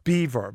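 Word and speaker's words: Belvoir: And there's a thing called, it's pronounced Beaver, but it's B-E-L-V-O-I-R. – Careful now. – No, it's B-E-L-V-O-I-R, but it's pronounced Beaver.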